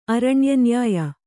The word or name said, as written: ಅರಣ್ಯನ್ಯಾಯ